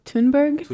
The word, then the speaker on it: Thunberg: So Greta, I think her last name is pronounced like Thunberg.